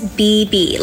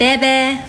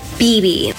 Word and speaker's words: Bebe: My name is BB, like two B's, not Bebe, BB.